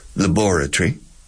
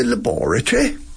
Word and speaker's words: laboratory: The British say laboratory. Laboratory, which I love. I love that word. Oh, it's in the laboratory. And the Americans are radically different. Laboratory.